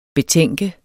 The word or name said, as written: betænke